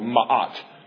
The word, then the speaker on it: Ma'at: Egyptian religious beliefs held that it was the Pharaoh's job to maintain what they called Ma'at. If you want to spell that, M-A-apostrophe-A-T. It's called Ma'at.